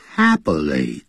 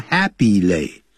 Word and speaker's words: happily: 所以 even so，即使如此， they live happily together，不要念成 happily，不是 happily， happily， happily， happily together，他们很愉快地住在一起，愉快地叫 happily， together 一起。